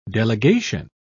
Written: delegation

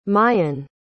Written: mayon

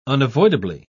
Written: unavoidably

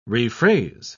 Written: rephrase

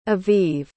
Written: aviv

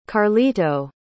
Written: carlito

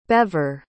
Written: bever

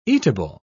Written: eatable

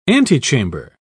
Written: antechamber

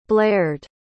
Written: blared

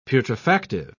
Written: putrefactive

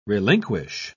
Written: relinquish

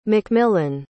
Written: MacMillan

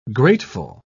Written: grateful

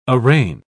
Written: arraign

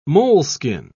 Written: moleskin

